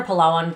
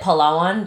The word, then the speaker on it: Palawan: Oh, so I spent 8 days total in Palawan or Palawan, depends on where you're from, how you pronounce it. Sorry, I'm going to pronounce it Palawan. That's just how I was taught by some locals.